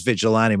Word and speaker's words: vigilante: and what I did was I looked it up even on the Cambridge Dictionary where they have the UK pronunciation and right. it sounds like this. Vigilante. Vigilante.